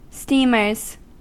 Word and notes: steamers: American English

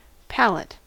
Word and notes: palate: American English

